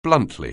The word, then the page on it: bluntly: anglais